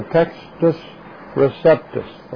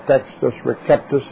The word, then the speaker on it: Textus Receptus: This printed text is commonly called the Textus Receptus, or Textus Receptus, if you want to pronounce that with a hard C, the received text.